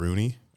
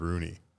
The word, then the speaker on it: Rune: I think she said Rooney, R-U-N-E. – I thought it was Rune. – So did I. But she said Rooney or Run.